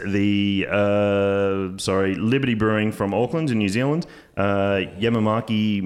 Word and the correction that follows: Yakima: Monster. I'm sure I'm pronouncing that incorrectly. – Yamamaki. Yakima.